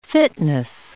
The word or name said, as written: fitness